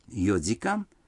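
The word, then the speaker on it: 四時間: Некоторые цифры в этих случаях произносятся не так, как обычно. Четыре часа – это йодикан, семь часов – ситидикан, а девять часов – кудзикан.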